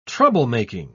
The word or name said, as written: troublemaking